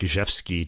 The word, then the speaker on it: Rzewski: So Paul Jacobs, Winsboro Cotton Mill Blues, composed by Frederick Zhevsky. Check it out. Zhevsky is spelled R-Z-E-W-S-K-I. Okay, so it starts with an R, not a Z.